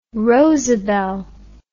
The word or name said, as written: rosabel